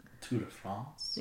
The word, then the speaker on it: Tour de France: Tour de France? – Yeah, but it's basically a mockumentary about it.